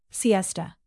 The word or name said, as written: siesta